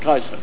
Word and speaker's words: Caesar: Caesar, but the reality that the Romans probably pronounce it Kaiser.